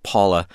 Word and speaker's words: PAVVLA: The artist was Paula. – Oh. – It's spelled P-A-V-V-L-A, pronounced Paula.